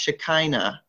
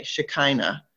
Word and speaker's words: Shekinah: And I might have said it wrong earlier. I think it's pronounced Shekinah. Okay, Shekinah. I think I said it wrong before. Shekinah.